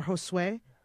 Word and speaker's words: Josue: Because Jose, I think it's Jose or Josue, but it's J-O-S-U-E. So it's like...